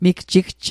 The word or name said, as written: migjigj